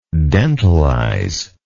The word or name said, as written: dentalization